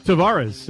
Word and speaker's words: Tavares: Tavares.